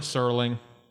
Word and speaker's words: Serling: Serling.